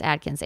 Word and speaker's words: Akins: It's Adkins, Akins, Adkins.